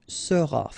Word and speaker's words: seras: All right, then tu seras, final S not pronounced, tu seras.